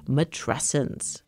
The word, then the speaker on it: matrescence: And then one day she came across a word that seemed to hit the nail on the head, matrescence. – and you pronounce it like adolescence.